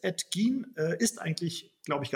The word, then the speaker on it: Gein: Oder die Deutschen ja. sagen Ed Gain, weil das so für uns so geschrieben wird. Aber Ed Gein wurde ausgesprochen ähm, auf dem basiert, äh, wie man äh, weiß, Norman Bates, einer der berühmteste Psychopathenfilm, nämlich Psycho.